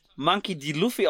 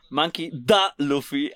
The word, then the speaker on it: Monkey D. Luffy: Ich fand's so lustig, der Moderator hat einfach das nicht als Monkey D. Luffy ausgesprochen, sondern hat gesagt Monkey da Luffy. Also so wie so ein, so ein Gangstername.